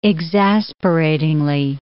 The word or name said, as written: exasperatingly